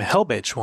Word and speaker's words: Helbich: I came across a woman with the last name Helbich once, which I imagine Helbich? She pronounces it Helbic, but it was like H-E-L-B-I-C-H.